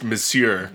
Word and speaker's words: Monsieur: The word is pronounced Monsieur. It's French.